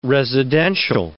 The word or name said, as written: residential